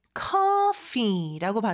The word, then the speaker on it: coffee: 우리는 커피라고 하지만 영어는 coffee라고 발음을 해요. 그래서 같은 키읔 같은데 사실 영어는 그 C 발음이 입 앞쪽에서 K 이런 소리라고 보시면 돼요.